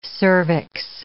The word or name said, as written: cervix